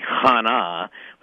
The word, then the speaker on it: Hannah: And so while we refer to her as Hannah, good Hebrew pronunciation would be Hannah, with that good guttural chet at the front of it.